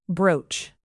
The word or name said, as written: brooch